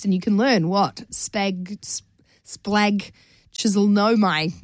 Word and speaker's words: splagchnizomai: Splagchisonomai. It's spelled S-P-L-A-G-C-H-N-I-Z-O-M-A-I. Splagchisonomai.